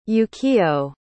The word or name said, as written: Yukio